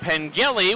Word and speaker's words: Pengelly: And Pengelly with a hard G, with a lot of accomplishments, including the fact that he's posted a weighted 4.1 GPA in engineering at New Mexico.